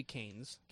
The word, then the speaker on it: Keynes: I say Keynes. – I say his name is supposed to be Keynes. – Keynes. Like Tim Keynes. – Yes, exactly. – Like candy canes.